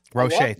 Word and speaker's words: Roche: I think he is Roche. Thank you. Thank you for the pronunciation correction.